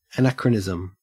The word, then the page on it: anachronism: Australian English